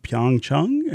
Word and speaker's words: Pyeongchang: Devin Scillian, evening news anchor on WDIV, visited Pyeongchang and will air tonight at 8 p.m.